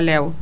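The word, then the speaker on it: Lew: ¿Héctor Lu o Héctor Lo? No sé. ¿Cómo se pronuncia tu apellido? – Leu. – O sea, Leu. Bien. L-E-W. O sea, no lo dije bien ninguna de las dos veces.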